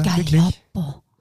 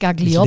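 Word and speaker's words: Gaglioppo: Gallopo. Ja, richtig Profi, nicht Gagliopo.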